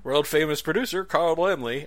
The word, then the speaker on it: Laemmle: And I'm going to keep saying his name now that I know how to pronounce it. Okay, ladies and gentlemen, his name is literally spelled L-A-E-M-M-L-E. I'm so glad that Steve is here to tell me how to pronounce that.